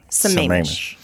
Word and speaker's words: Sammamish: I'm saying it wrong. – Sammamish. – Sammamish.